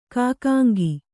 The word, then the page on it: ಕಾಕಾಂಗಿ: Kannada